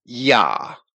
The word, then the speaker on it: Yah: And Strong's goes on to say that it means praise ye Yah, which he spells with a J, J-A-H. Spelling it with a J rather than a Y does not change the proper pronunciation.